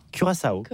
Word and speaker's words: Curaçao: Curaçao. Donc comment on prononce ça? – Curaçao.